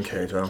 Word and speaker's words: Cato: I think I'm saying that right. It's C-A-T-O. – Yeah, that's Ryan Cato.